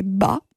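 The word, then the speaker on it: ba (八): En mandarin, la prononciation du numéro 8 est ba. Cette prononciation est très similaire au caractère qui signifie papa ou père.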